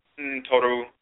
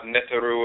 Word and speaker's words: Ntoru: Ntoru, Ntorotu, mispronounced sometimes Neteru or Netertu, Ntoru, Ntorotu, the forces in nature, the deities, the goddesses and gods.